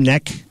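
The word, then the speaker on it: Knecht: Speaking of the Facebook page, there's a guy named Jim Knight who, is that how you pronounce his last name, Killer? It's Jim... – Neck? – Jim Neck. – Maybe it's K-N-E-C-H-T.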